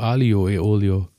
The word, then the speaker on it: Aglio e Olio: Okay, der Wörner, der wöchentliche Burner ähm, ist diese Woche für mich, gab es nämlich dreimal ähm, Spaghetti, ich weiß nicht, ob ich das richtig ausspreche, ich habe keine italienischen Wurzeln, Aglio e Olio mit Knoblauch. – Ich glaube Alio e Olio. – Ja, nehmen wir so. Alio e olio. Ich sag immer Aglio.